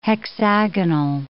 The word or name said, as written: hexagonal